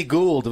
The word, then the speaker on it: Gould: Robbie Gould. Was that all right? Was the pronunciation okay there? – Yes, all right, very good.